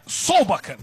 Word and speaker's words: Solbakken: É o Solbakk, ou Solbakk, não me lembro a pronúncia correta dele, acho que é Solbakk, né? É isso? – É, Solbakken.